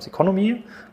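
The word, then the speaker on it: Ceconomy: Mittlerweile sozusagen labelt ihr euch ja quasi an der Börse nochmal unter ähm, Seconomy, glaube ich, wenn ich das richtig ausgesprochen habe. Ja, ist richtig ausgesprochen, aber ist eigentlich unser Gesellschafter.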